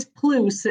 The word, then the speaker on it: Pluse: Alexis, how do you pronounce your last name? – No worries. It's Pluse. It's Pluse. Like a goose.